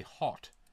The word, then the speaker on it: Haute: How's it spelled? – H-A-U-T-E. – H-A-U-T? – Yeah. – I would say Haute. T-E. – Yeah, I'd probably say Haute, too.